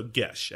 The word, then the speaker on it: geis: So gesh, actually. But it's spelled G-E-I-S for those out there.